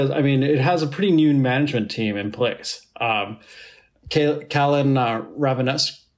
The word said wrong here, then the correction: Rovinescu: Callum Rovinescu.